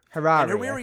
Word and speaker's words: Hariri: Hariri, I think. – I don't know. Pronunciation is of people's names has never been much recent. The second vowel is an A, so it's not E.